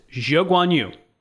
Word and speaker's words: Zhou Guanyu: He verified to Ted that, yes, that is how he would like his name pronounced. So I will do my best to pronounce it Zhou Guanyu.